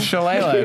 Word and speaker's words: Shillelagh: I love so much how you pronounce Shillelagh as Shillelagh. – It's Shillelagh. That's what it is.